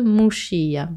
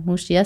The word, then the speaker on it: Muxía: De Finistère et de Muxía. Muxía, ça s'écrit M-U-X-I-A.